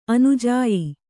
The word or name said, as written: ಅನುಜಾಯ್